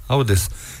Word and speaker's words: Otis: Sí, Audes. Y se escribe Otis.